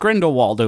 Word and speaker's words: Grindelwald: We're just Grindelwald over here.